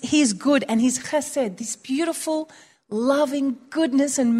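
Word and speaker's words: Chesed (חֶסֶד): Chesed. Chesed. We don't have that ch sound in English, but it's chesed.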